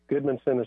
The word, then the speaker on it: Gudmundson: Goodmanson is spelled G-U-D-M-U-N-D-S-O-N, Goodmanson, okay.